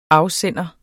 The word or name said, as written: afsender